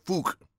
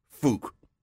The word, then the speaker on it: Phuc: And she was unhappy that he did that because her first name is spelled P-H-U-C and her last name is Wynn. How would you say that name? I don't think we could say that here on the radio. – No, you can't. It's Fook. – Fook? – Fook, yes. It's pronounced Fook.